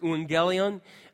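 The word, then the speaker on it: euangelion (εὐαγγέλιον): In Latin, in Greek, it's pronounced euangelion, and then they can't pronounce use in Latin, so you have a V, evangelion.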